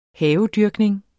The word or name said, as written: havedyrkning